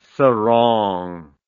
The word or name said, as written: serang